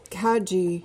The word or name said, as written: cadgy